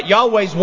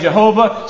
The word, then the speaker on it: Yahweh: Now, it's spelled as Y-H-W-H. All right, Yahweh's one of the pronunciations. Some say Jehovah, some say Yehovah, some say a couple of different things. It's not all the same terminology or the same pronunciation.